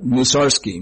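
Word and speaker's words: Mussorgsky: And the person came back, well, that's not how you pronounce it. It was Musarsky.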